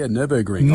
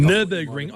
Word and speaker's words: Nürburgring: Yeah, I know all about, is it Nürburgring? How do I say it? – Oh, yes. Yeah, no, that's not too bad. Yeah, Nürburgring. – Nürburgring.